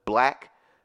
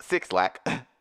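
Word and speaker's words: 6LACK: She signed with powerhouse Interscope and Love Renaissance, a label based in Atlanta and home to Black, should be pronounced Six Lack, and BRS Cash of Throat Baby fame.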